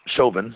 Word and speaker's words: Chauvin: Chauvin, is that how you say his name? – Yeah.